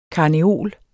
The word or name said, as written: karneol